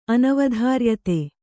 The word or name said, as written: ಅನವಧಾರ್ಯತೆ